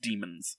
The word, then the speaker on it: Daemons: Is it Daemons? – The A-E. – Yeah, Daemons. – Yeah, Daemons. But it's still pronounced demons.